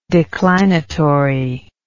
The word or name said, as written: declinatory